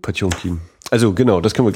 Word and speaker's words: Potemkin: Ich habe früher auch lange Zeit gedacht, Panzerkreuzer Potemkin steht doch da, also spricht man das so, bis ich dann irgendwann mal darüber äh, aufgeklärt wurde, dass man das Potjomkin ausspricht.